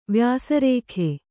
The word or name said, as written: ವ್ಯಾಸರೇಖೆ